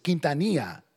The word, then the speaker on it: Quintanilla: And someone said, another Hispanic said, oh, that's Quintanilla. That's how you pronounce it, Quintanilla.